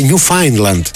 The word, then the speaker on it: Newfoundland: Για το φίλο που λέει Ακτύπη, επειδή έχεις πάει πολύ πέρα από το proficiency και σε αρέσουν αυτά, το Newfoundland προφέρεται Newfoundland πληροφοριακά.